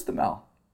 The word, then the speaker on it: Thamel: Did I pronounce his name? – I think so. I've always thought it was Thamel.